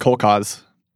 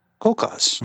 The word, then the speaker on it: Kolkhoz: I actually went and like played the pronunciation. It's just Kolkhoz. – Kolkhoz. Very easy. – Much easier than you think.